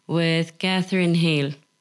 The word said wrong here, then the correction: Heigl: Catherine Heigl.